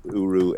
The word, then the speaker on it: Uru: URU. So do you pronounce that Uru or is it just URU? – No, I call it Uru.